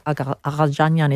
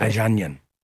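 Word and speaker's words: Agagianian: So how could I not know about Cardinal Agajanyan is how you'd pronounce it. – Agajanyan.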